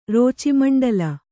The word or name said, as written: ರೋಚಿಮಂಡಲ